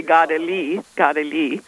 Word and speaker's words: Kateri: Oh, well, Kateri was, or as in Mohawk, they say, Gadali.